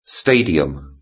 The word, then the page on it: stadium: British English